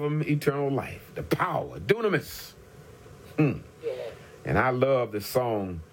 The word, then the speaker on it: dunamis: Now, the Greek word for power is dunamis. It's spelled D-U-N-A-M-I-S. Dunamis. D-U-N-A-M-I-S. Dunamis. And this is the term from which we get the English word dynamite.